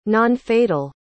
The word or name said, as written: nonfatal